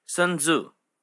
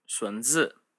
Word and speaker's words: Sun Tzu: He is most often called in the West Sun Tzu, though a more accurate Mandarin pronunciation would be Sun Zi.